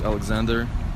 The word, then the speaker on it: Oleksandr: My name's Alexander Denisuk.